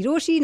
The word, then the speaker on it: Rochie: मेरा नाम है मिर्ची रोशी.